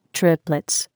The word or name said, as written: triplets